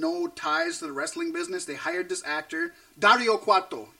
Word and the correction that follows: Cueto: Thank you. Dario Cuarto. And I'm probably saying it wrong now. Dario Cueto. And he's amazing.